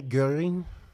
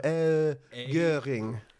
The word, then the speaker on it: Göring: Oh, to make that vowel sound, just go eh. Like, do make an S sound, but round your lips out. So, Göring.